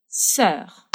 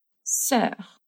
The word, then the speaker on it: sœur: Sœur. Sœur.